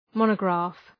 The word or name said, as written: monograph